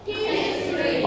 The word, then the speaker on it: history: History. Only two syllable.